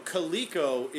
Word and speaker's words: Coleco: Coleco. You said Colco. Coleco is the name of the action figure line.